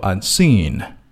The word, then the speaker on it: unseen: unseen 这个词呢有两个音节那么重音是落在第二个音节读作 unseen